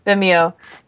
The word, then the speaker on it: Vimeo: He put it out on, how do you say it? Vimeo? – Vimeo.